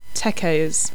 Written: techos

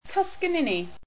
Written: tuskaninny

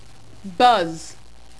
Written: buzz

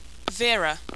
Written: vira

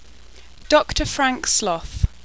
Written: dr frank sloth

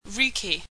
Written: ruki

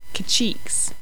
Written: kacheeks

